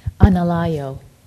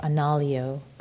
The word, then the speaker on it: Analayo: Analayo, A-N-A-L-A-Y-O, Analayo, Bhikkhu Analayo, or Analyo could be pronounced as well.